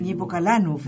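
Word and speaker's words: Niepokalanów: Nipokalanov, es que aprendí a pronunciarlo esta palabra, allí.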